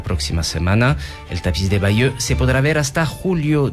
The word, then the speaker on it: Bayeux: El tapiz de Bayeux.